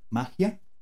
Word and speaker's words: Mahya: M-A-H-Y-A, magia.